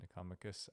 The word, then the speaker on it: Nicomachus: Let's talk about the Aristotle's ethics, the Nichomachean Ethics, so named after his son, Nicomachus. I think that's the correct pronunciation.